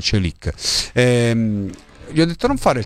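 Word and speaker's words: Çelik: E io gli ho detto: attenzione, non fare il tifoso medio, perché Celic, si pronuncia così, ve l'ho fatto ascoltare prima, ce l'ha ancora.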